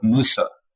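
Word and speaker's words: Mose: that even hints at the fact that this word Mosey, which we're pronouncing as Mosey, but it is not a Spanish word and wasn't actually even pronounced like that. If you look at a ton of the records of the time, even maps of the time, it's very clearly labeled Fort Musa.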